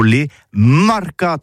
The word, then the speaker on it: marcats: Mais en gascogne toulousain, c'est prononcé les marcats avec un A.